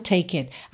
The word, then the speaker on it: take: Come, take it. Atenção, olha, come tem um E que não é pronunciado.